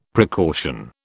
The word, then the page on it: precaution: British English